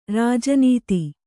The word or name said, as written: ರಾಜನೀತಿ